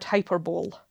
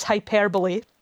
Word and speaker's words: hyperbole: It is, of course, hyperbole. And this is one of the reasons why I like this word so much. What a bonkers pronunciation. – Yeah. – Every other word that starts with hyper, it's pronounced hyper. I can't think of another example where you stress the second syllable rather than the first.